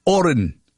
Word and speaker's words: Horan: Oh, yes, he prefers the silent H, Oren. H-O-R-A-N, Nile Oren.